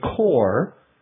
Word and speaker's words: corps: This particular expedition was called the Core of Discovery. A core, C-O-R-P-S, Notice that we don't pronounce the P or the S because it comes from French, is a group of people working together.